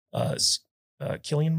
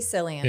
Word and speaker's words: Cillian: Cillian Murphy's character, is that how you pronounce it? – I don't think anyone knows. I say Cillian. – Yeah, I say Cillian. I think I do say Cillian Murphy. – I think it's a hard K.